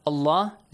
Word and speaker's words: Allah: Allah is pronounced heavily. Why? Because Dhamma sound is before. If there is a kasra, you pronounce it lightly, yes?